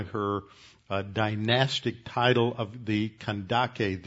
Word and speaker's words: Kandake: the Old King James translated the K. There's no sibilant C sound. That's in the like in Candace, you have a hard C at the beginning and then a soft sibilant C like an S at the end. In Greek, you don't have that. You just have the K, which is a hard K, Kandake.